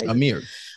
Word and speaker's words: Amiri: Amiri.